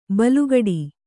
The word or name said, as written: ಬೆಲೆಗುಡು